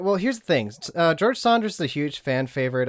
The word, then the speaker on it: Saunders: It's pronounced Saunders and not Sanders, right? – I'm assuming it's Saunders. Sanders is spelled differently.